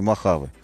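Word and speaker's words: Mojave: А правильно теперь говорить Махаве? А с ударением куда, Сергей Волк, скажите? Сергей Волк пишет, что Махаве.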